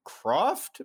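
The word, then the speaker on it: Krofft: They're like the first time I saw them, basically there's a Sid and Marty Croft. Am I saying that right, Jake? – I think so, yeah.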